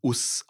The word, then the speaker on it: Osu: Ja, äh, die OSU-Strategie wird OSU geschrieben, aber wird US ausgesprochen. Das ist äh, die englischsprachige Transkription des japanischen Wortes US.